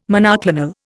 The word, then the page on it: monoclonal: Indian